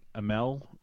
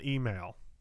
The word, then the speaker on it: Amell: Hey, you know, I follow Stephen Amell, I think that's how you say his name. The guy that plays Arrow. – Oh, yeah. Stephen Email. – No way. Email, even though it's spelled with an A? – No, I'm just kidding.